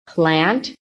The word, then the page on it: PLANT: American English